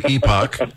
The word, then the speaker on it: Epoch: They don't know how to pronounce it. They call it Epic, but it's actually Epoch.